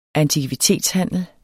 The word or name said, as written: antikvitetshandel